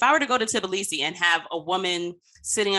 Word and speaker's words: Tbilisi: I mean, Wanda, how do you pronounce T? Is it TB? Sibi? – It's Tbilisi. – Oh, way the fuck off. Tbilisi. Thank you for that.